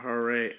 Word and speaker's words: Hoorae: Yeah, Hooray. H-O-O-R-A-E. That's how she spells it, but that's how you pronounce it.